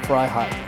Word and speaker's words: Freiheit: It's spelled Fry, F-R-Y-H-D-E, Fry High.